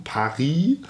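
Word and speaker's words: Pâhry: Samaris und Paris, also wie Paris ausgesprochen, aber anders geschrieben und eben besagtes Brüssel, ja, also wie Brüssel, nur mit einem S.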